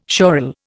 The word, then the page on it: sural: Indian